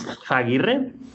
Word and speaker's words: Zaguirre: I mean, it's in Spanish, it's Zaguirre.